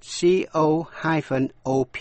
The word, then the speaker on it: co-op: Nhưng âm O đọc là O trong hay là A trong chữ cop, C-O-P, cop là cảnh sát, a traffic cop, cảnh sát giao thông. Còn C-O-O-P đọc là co-op, viết tắt là chữ cooperative, nghĩa là hợp tác xã.